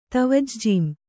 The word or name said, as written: ತವಜ್ಜೀಂ